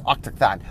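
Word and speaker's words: Autochthon: Octothon Worm. This is hard to pronounce. Octothon. O-U-T-O-C-H-T-H-O-N. Worm. Worm as in big, giant dragon without wings, as opposed to a W-O-R-M.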